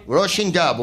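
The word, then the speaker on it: Róisín Dubh: On the 25th of July, we'll be in Galway at the, how do you pronounce it, R-O-I-S? Roisin Dub. We'll be at the Roisin Dub in Galway.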